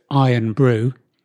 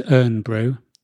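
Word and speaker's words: Irn-Bru: And was told often that it was urn brew. And the person who was with me was from up north. – Because it is spelled IRN, isn't it? Urn.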